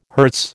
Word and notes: hertz: American English

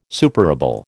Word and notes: superable: American English

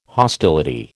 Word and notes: hostility: American English

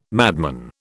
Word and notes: madman: British English

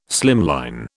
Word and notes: slimline: British English

